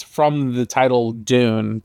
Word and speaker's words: DVNE: D-U-N-E, like the movie? Or D-V-N-E? – I think it's D-V-N-E, but they still pronounce it. They still pronounce it Dune. – I thought it was Divine.